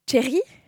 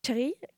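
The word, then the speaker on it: Čechy: Les Tchèques, eux, ils l'appellent... Alors là, encore une fois, ma prononciation en tchèque. Tchéri. Tchéri. Quelque chose comme ça.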